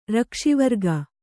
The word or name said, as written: ರಕ್ಷಿವರ್ಗ